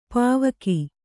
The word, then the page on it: ಪೌವಗೆ: pauvage